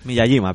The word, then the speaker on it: Miyajima: Muy bien, después de, Hiroshima llegamos a la isla a la isla de Miyajima, que no sé si lo pronuncio bien. – Miyajima, pero bueno, está bien, está perfecto.